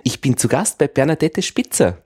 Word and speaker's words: Bernadette: Bernadette ist ein französischer Name. – Ja. – Und daher wird der letzte Buchstabe des E nicht ausgesprochen.